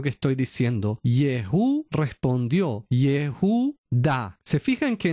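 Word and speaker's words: Yehudá (יהודה): Bueno, si leyéramos en hebreo, entenderíamos que justamente Yehudá, la palabra Yehudá, que dicen Judá en castellano, omitiendo una sílaba, Yehudá tiene al principio el nombre mismo del Señor.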